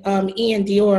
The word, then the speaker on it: Iann Dior: Now, when it comes to Electric Field Entertainment, you manage people like Rich the Kid, Ian Dior. Am I saying that right? – Yes.